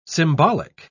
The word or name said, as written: symbolic